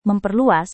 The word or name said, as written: memperluas